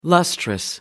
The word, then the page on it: luster: American English